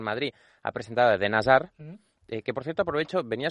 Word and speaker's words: Hazard: Él es belga, se pronuncia, es Eden Hazard. Esta es la forma correcta de decirlo. Además, a mí me han comentado algún compañero que tampoco se pronuncia ni la H, por supuesto, ni la D final, casi.